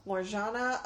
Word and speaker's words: Morjana: No, they pronounce it as J's, right? Like, je ne sais quoi. Like, je. So, Morjana Alawi and Mylene Jampanoy.